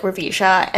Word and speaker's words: Ravisha: And the facilitator on the first day was like just calling on random people and said my name like Ravisha. And then as soon as they said, they're like, oh, am I saying your name correctly? And I was like, yeah. And I was like, that's exactly how you say it, like no problem.